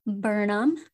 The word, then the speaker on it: Burnham: And so my birth name is actually Stephanie Burnham. It's spelled like Burnham. Kind of sounds like Birmingshire or something like that.